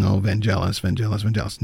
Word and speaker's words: Vangelis: It never came through, but at the same time, he told me that the name is pronounced with the hard G, so it is Vangelis.